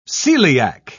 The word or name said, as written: coeliac